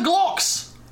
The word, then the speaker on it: Gloktz: No, mate, we're the Glocks. G-L-O-K-T-Z. Glocks. – If you put the Glocks. T at the front, it can just be a silent T.